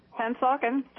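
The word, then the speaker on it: Pennsauken: Pensauken, New Jersey. Am I saying that right? – Pensauken.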